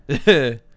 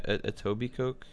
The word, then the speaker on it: Etobicoke: Can you pronounce that city name? – Oh, Jesus. – Etobicoke? – Etobicoke. Yeah, Etobicoke. – Etobicoke, Ontario. That's right across the border from Nyack, from where I am.